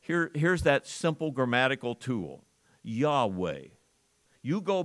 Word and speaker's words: Yahweh: If you say I am in Hebrew, you say Yahweh. That's how you pronounce it.